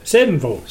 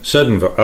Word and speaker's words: Seddonville: And how do you pronounce it? Sedenville. Seden Sedenville. Okay, I can hear it now.